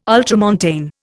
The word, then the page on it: ultramontane: Indian